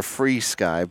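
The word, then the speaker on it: FrSky: But technically, we're Free Sky. But yeah. – Free Sky? – Yeah, it's actually what the name spells out.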